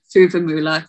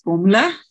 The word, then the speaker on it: Fuvahmulah: I call it Fuvahmulah because that's how it's spelled, but the locals pronounce it Formula.